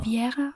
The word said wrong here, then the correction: Rivera: ich kann es nicht so gut aussprechen. – Rivera.